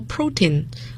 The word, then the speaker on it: protein: OK, the next one is 蛋白质，我们叫 protein 要注意它的发音，重音在第一个音节，蛋白质。